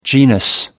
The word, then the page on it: genus: American English